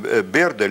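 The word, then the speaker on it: Byrd: И было четыре экспедиции Берда, или Берда, мы по-разному, ну, все-таки Берд, так если правильно произносить.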